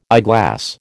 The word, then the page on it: eyeglass: American English